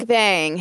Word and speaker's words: Mukbang: Mukbang? – Mukbang.